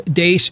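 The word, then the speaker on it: Deace: You can also like us on Facebook, follow us on Twitter at Steve Dace Show.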